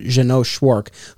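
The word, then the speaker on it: Jeannot Szwarc: but Jeannot Schwartz, who would go on to direct on Smallville. – Yes. That's how I've heard the name pronounced. So that's how I would say it. Jeannot directed Jaws 2.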